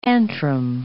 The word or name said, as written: antrum